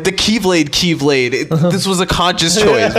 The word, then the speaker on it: Keyblade: Someone in the department, in Square Enix department, was like, refer to that as Kyblade. – Yes. – The Greek pronunciation.